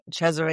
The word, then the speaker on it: Cesare: Exactly. Cesare is the pronunciation Italian.